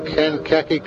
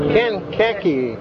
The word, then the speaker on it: Kankakee: You're pronouncing it wrong. – No, I always pronounce it good. Oh, it is not. Kankakee, Illinois. Kankakee? Who's going to say that? That sounds like Hawaii. – No, it's Kankakee. Kankakee, Illinois. The river.